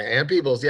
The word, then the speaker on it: Ann Peebles: Yeah, Ann Peebles, yes.